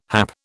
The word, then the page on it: hap: British English